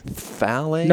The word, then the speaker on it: phthalate: Phthalate. – No, you don't even have to do the just say F like phthalates.